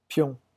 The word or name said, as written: pion